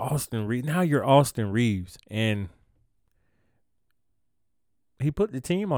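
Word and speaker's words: Reaves: Yes, y'all heard me say it right. Austin Reeves.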